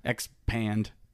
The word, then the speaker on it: Xpand: Well, it's because it's expand, but it's only spelled with the X. – Xpand. – No e. Xpand.